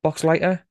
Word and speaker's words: Boxleitner: Is it Boxleitner? Box Lighter. I never get it right. – Box That's Light- the way I've always pronounced it. – So Boxleitner. Yes, he who obviously went on into more sci-fi roots and was in Babylon Five years later.